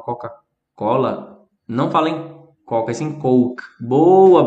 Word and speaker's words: Coke: Coke. C-O-K-E. Coke. Não é pra falar có, não, tá bom? É coke. Se você falar o có, vai parecer aquela palavra que eu falei.